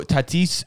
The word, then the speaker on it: Tatis: So how do you say his last name? – Fernando Tatis Jr. Yeah. – Not bad.